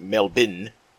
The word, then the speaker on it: Melbourne: During the stabbing attack that occurred over the weekend at the Bourke Street Mall in Melbourne, or as my Australian friends tell me it is pronounced Melbourne, Australia, one man would emerge as a hero.